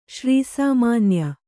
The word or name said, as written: ಶ್ರೀಸಾಮಾನ್ಯ